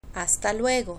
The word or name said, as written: hasta luego